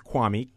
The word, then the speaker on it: Kwame: It's my name is Kwame, K-W-A, M as in Mary, E.